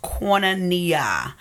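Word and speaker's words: koinonia (κοινωνία): The word for share in Greek is koinonia, pronounced koinonia. It speaks of the thing that is shared in common as the basis of fellowship.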